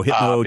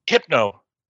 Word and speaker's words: Hypno: Oh, Hitmo Chan or Hitman or Hitmo? – Hypno. – Oh, Hypno. Hypno. Okay. – Hypno, not Hitmo.